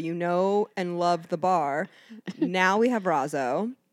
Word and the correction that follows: razzo: Rotzo. Yeah, you say the T like pizza. – Rotzo. Oh my God, I've been saying it wrong.